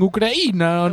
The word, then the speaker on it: Ucraína: Ucraína pronúnciase como cocaína.